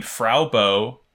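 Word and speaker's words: Fraw Bo: And the girl is named Frau Bo, F-R-A-W, not F-R-A-U, which is how you would expect Frau to be pronounced.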